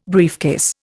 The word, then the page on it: briefcase: Indian